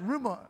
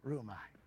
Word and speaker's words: rhyomai: It's spelled R-H-Y-O-M-A-I, but it's pronounced rhumai. There we go, rhumai.